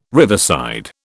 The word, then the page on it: riverside: British English